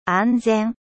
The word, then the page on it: sûr: French